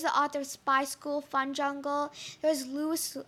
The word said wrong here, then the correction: Lois: Lois Lowry.